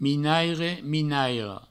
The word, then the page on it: minaire: minaira; minairo